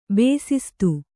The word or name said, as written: ಬೇಸಿಸ್ತು